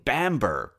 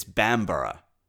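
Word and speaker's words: Bamburgh: Second, I've been saying Bamber, because that's also how it's spelled. Apparently, though, it's Bambara, because it's in the North, and they love syllables so much, they just had to add another.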